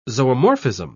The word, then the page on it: zoomorphism: British English